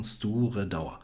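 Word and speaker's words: Stiùireadair: Auch wieder völlig abgefahren geschrieben auf Gälisch, aber im Grunde ausgesprochen Sturedauer.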